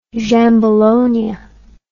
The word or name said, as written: Giambologna